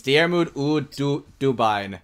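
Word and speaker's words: Diarmuid Ua Duibhne: You cut it off at the Diarmuid. It's Diarmuid Ú Dubin.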